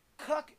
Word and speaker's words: Kuk: Kuk, K-U-K, excuse me. Kuk is spelled K-U-K. Pronounced Kuk.